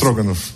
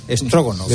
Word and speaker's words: Stroganov: No hemos podido disfrutar de dos de los platos favoritos aquí, que son los blinis y el bife strogone, o strogonefo, ¿cómo se dice? – Stroganov. – Stroganov.